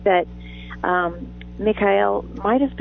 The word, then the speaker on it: Mikhail: And it's so funny, Mikhail wanted to go to, that's not how you pronounce it. How do you pronounce it? – Mikhail.